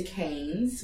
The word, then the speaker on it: Cairns: I went to Sydney, and I also went to Cairns, which is actually spelt like Cairns for Americans. – C-A-R-N-E-S? – C-A-I-R-N-S. – I-R-N-S. – But it's pronounced like a cane.